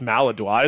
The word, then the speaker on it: Maladroit: I do remember it's Maladroit, isn't it? – Well, that's how Americans say it. Yeah, but it should be pronounced Maladroit. It's like LaCroix. Like, that's pronounced LaCroix.